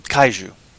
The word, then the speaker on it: kaiju: It's called Gaiju. Or I'm probably manhandling the pronunciation of the word, but then... I don't care. I can live with it. Kaiju. Sorry, yeah, Kaiju.